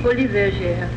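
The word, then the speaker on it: Folies Bergère: How do you say it? It's folie. – Folie bergère.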